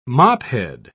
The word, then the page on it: mophead: British English